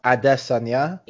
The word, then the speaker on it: Adesanya: Apparently, Israel Adesanya's name is supposed to be pronounced Adesanya. – It is Adesanya, but yeah.